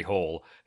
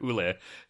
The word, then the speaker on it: Hole: It just says Harry Hole. It's like, come on, guys. It's a film. You don't have to be that faithful to the novel. Change his fucking name, or at least pronounce it Hule.